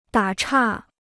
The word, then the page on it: 打岔: dǎ chà